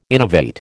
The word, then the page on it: innovate: American English